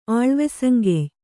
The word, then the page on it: ಆಳ್ವೆಸಂಗೆಯ್: kannada